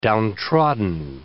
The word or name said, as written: downtrodden